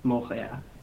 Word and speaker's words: Montréal: in French, they don't pronounce the T. It's more like Montréal.